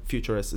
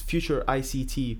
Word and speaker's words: FuturICT: So Futurist, okay, and I've seen kind of the website, and we'll put links up to the website for Futurist. It's Future ICT, but Futurist is the shortened form? – It's essentially, we write it Future ICT, yep. But it gets pronounced in both ways.